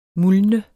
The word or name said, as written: muldne